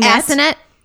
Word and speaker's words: Assonet: So again, 1653, Freetown was originally settled in, I assume it's just Assonet Bay. – Yep. – Because it is spelled Assonet. – Assonet. Yeah.